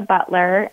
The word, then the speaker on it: Butler: Elisha Butler, and my first name is spelled E-L-Y-S-I-A, and then Butler is B-U-T-L-E-R.